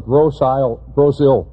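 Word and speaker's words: Grosse Ile: Even if you live in Wixom, or Northville, or Rockwood, or Flat Rock, or Taylor, or Farmington, or Gross Hill, that's how you say it.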